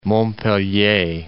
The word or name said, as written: Montpellier